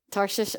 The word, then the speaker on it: Tarshish: Am I saying it right? Tarshish. Okay.